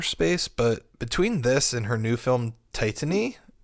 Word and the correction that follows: Titane: I think it's Titane. I worked at a movie theater and I missed it when it was there. But we had like so many people coming in being like, yes, it's pronounced Titane.